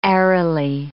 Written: aerily

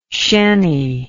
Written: shanny

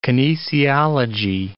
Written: kinesiology

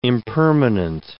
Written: impermanent

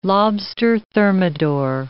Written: lobster thermidor

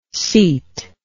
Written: cete

